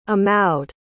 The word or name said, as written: amaud